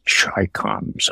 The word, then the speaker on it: Chi-coms: For those of younger age, Chi-coms are Chinese communists, but they're always pronounced Chi-coms, okay?